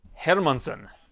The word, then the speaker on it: Hermansson: Short notice assignment, even money against Jack Hermansson. That's how he told me it's pronounced. Everybody says Hermansson. That's the Swedish pronunciation, if you will.